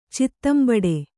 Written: ಚಿತ್ತಂಬಡೆ

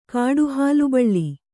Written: ಕಾಡುಹಾಲುಬಳ್ಳಿ

